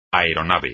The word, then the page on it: aeronave: espagnol